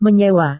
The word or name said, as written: menyewa